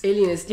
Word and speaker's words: Alienist: Ik heb ook heel hard aan de, nu ga ik het weer fout uitspreken, die Lionist? Alienist? – Alienist, ja, ik heb hem nog niet gezien.